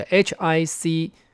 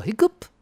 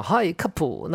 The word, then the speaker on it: hiccup: 就 HICCUP。好，它的发音很简单呢，叫 hiccup，还有 hiccup，就是打嗝的概念。可是你不能看人家讲 Hi cup，那这个会觉得很尴尬，对不对？